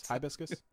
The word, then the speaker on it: hibiscus: Thank you for pronouncing hibiscus correctly. – Hibiscus? Hibiscus? Hibiscus? – Hibiscus.